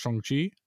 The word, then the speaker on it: Shang-Chi: And Shang-Chi, which we found out is pronounced Shang-Chi, has come out.